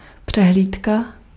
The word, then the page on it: tentoonstelling: /tənˈtoːnˌstɛlɪŋ/; /tɛnˈtonstɛlɪŋ/